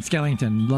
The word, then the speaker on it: Skellington: Home of Jack Skellington. – Skellington, lovely.